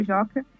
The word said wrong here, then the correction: Jockey: no Jockey, Jockey até falando errado, Jockey Club, uhum.